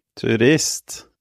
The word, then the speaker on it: Turist: Turist. Si turist.